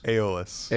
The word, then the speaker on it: Aeolus: Aeolus.